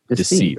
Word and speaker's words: deceit: When Jesus saw Nathanael approaching, he said to him, "Here truly is an Israelite in whom there is no decreet." How do you say that word there? – Deceit, right? – Yeah, deceit.